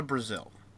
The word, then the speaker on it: Brazile: Donna Brazile. Like the country. Brazil? It just has an N. Okay, it just like has an E at the end.